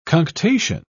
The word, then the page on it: cunctation: British English